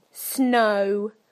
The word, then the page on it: snow: British English